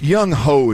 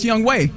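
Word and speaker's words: Younghoe: And Reg, you threw – it's Young Ho is the kicker, by the way, for Atlanta. – It is pronounced Young Way, though.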